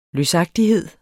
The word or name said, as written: løsagtighed